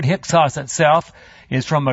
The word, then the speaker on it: Hyksos: Anyway, Hyksos.